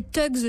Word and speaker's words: thugs: Alors, autre société secrète dont je découvre, en fait, l'existence dans votre dossier spécial, ce sont les thugs, je prononce bien ? – Oui. Parfait.